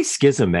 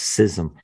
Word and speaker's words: schism: I'd have to go with the hard C with schism there.